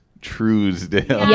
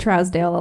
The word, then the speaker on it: Truesdale: But, and I noticed in your bio, it says in parentheses, pronounced Truesdale. – Yeah. – Trooz. – Yeah, we get Trousdale a lot.